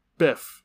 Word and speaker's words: Biff: Did you say Buff? – Biff. – Biff.